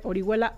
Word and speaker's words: Orihuela: Y sí, efectivamente, Orihuela, O-R-I-H-U-E-L-A.